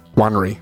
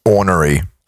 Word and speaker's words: ornery: Mean and onery. What does that mean? What do you mean by onery? – Honery, maybe? – Honery? It's O-N-E-R-Y. – Ornery. Ornery is how it's pronounced.